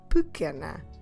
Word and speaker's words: pequena: P-E-Q-U-E-N-A. Pequena. So you've noticed that the U is silent, but also that first E is silent. We're stressing que, the second to last syllable in the word.